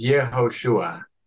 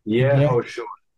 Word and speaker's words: Yehoshua: Joshua in Hebrew is Yehoshua. Yehoshua.